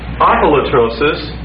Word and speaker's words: apolytrosis: It's a strengthened form of this word right here, and it's spelled like this, apolytrosis, and that would be spelled A-P-O-L-U-T-R, long O-S-I-S.